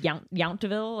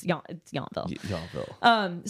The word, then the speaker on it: Yountville: I think technically it's pronounced Yountville, like like in Fury, but it's Yountville.